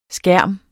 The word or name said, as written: skærme